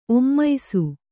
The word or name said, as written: ಒಮ್ಮಿಸು